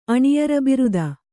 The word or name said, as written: ಅಣಿಯರಬಿರುದ